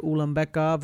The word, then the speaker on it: Ulambekov: Tagir Ulambekov versus Alan Nascimento.